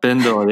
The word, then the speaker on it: Bindle: Am I saying it right? Bindle? – Bindle, yeah. B-I-N-D-L-E.